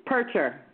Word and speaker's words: Purtscher: How do you say your last name, hon? – It's Purtscher.